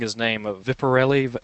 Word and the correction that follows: Zeppeli: the one that JoJo Zeppeli? – Learned. Zeppeli.